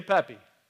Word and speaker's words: Pepi: Pepi in ancient Egyptian is pronounced Charles. No, it's just Pepi.